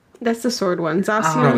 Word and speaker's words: Zacian: Zacian? There you go. – That's it. Yeah. – That's close enough. – We'll call that the correct pronunciation.